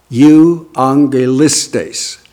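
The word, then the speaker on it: euangelistes (εὐαγγελιστής): The word for evangelist, the specific word that the Holy Spirit has given, is the word euangelistes. Euangelistes. I'll spell it with English letters for you. It's euangelis. T-E-I-S, euangelistes.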